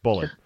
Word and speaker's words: Bulleit: Bullet, B-U-L-L-E-I-T, but yeah, Bullet. – It's pronounced Bullet.